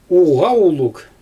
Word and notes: uɣawuluɣ: Xyzyl

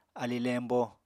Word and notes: ali lembo: apatani

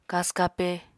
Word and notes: kasw-kape: Apatani

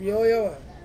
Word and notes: mioya va: Ansus

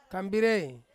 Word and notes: kambirei: Ansus

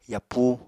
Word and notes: yapúu: Apatani

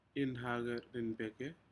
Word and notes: inhaceriapeke: Aneityum

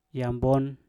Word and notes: nyabon: Mpur

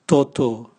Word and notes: toto: Raga

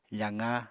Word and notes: faqa: apatani

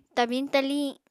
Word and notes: tabiñ-taliñ: Apatani

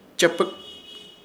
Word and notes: Chepɨk: Ishir Chamacoco

